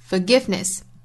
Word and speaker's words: forgiveness: Forgiveness 这个词包含三个音节，是三拍的 da da da。 Forgiveness 后面的这个 ness 可以读成 ness，也可以读成 ness。